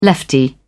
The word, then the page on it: lefty: British English